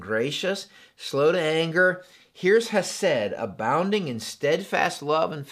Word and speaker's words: hesed: But what I want you to know is that this word, how they translate this, is the Hebrew word hesed. Say that with me, that's kind of fun. Hesed. If you didn't spit on your screen, you're not saying it right.